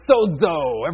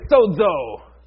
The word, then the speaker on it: sozo: The word that is used here, the faith has made you well, it is the Greek word sozo. Everybody say sozo. You have to say it like an angry man.